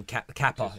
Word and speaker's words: Kepa: We call him Kepa, is his first name. Okay. Yeah, Kepa.